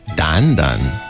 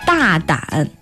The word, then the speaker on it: 大胆: 胆胆。 – 大胆。